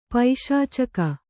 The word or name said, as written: ಪೈಶಾಚಿಕ